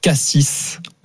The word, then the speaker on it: Cassis: Alors, les gens qui prononcent Cassis au lieu de Cassis. – Pour la ville. – Exactement. – Et on dit pas Cassis ? – Et non. – Mais non, on dit Cassis.